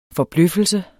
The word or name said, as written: forbløffelse